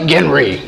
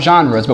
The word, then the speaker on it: genre: It's like, it's like Genry, you know, but it's really pronounced genre because it comes from the French word, which means kind or type.